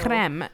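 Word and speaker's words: crème: Choose a creme drink or cream. I'm just gonna say cream because I know. – Creme. No, it's a creme.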